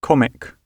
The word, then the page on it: comic: British English